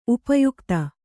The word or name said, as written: ಉಪಯುಕ್ತ